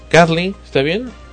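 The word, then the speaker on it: Kathlen: Kathleen, ¿está bien? – Sí.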